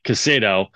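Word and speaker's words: Caicedo: So as soon as Chelsea comes in for Lavia too, as a backup to Cicado, I just butchered that name. – Casado. – Big time. Casado.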